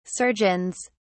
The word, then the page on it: surgeons: British English